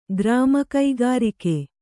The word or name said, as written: ಗ್ರಾಮಕೈಗಾರಿಕೆ